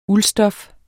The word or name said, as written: uldstof